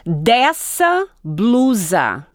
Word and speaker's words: dessa blusa: If a word is written with two S's, it's going to sound like an S. – Right. – And if it's written with one S, it's going to sound like a Z. – A Z when it's between vowels. – Right. – Yeah. – And so once again, the pronunciation mm-hmm. is? – Dessa blusa. – Dessa blusa. – Blusa. Right? Yeah, like Z.